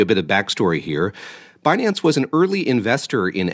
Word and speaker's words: Binance: Tell us about Binance. Am I pronouncing it correctly? – You are.